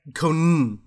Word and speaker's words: Conan: You said Conan? – Conan. – Conan is the correct pronunciation.